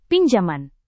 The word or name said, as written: pinjaman